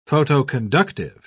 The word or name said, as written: photoconductive